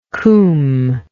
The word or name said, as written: cwm